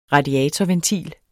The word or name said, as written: radiatorventil